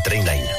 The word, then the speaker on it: Trainline: S'escriu millor preu garantit. Es pronuncia Trainline.